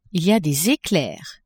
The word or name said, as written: il y a des eclairs